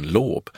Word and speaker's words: Loob: Efternamnet det är estniskt och uttalas egentligen lob, vilket betyder skapar.